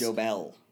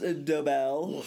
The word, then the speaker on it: Dobel: Dobel. – You need to clear your glasses. There, push them up the bridge of your nose. Here, clear your throat. – It's pronounced Dobel.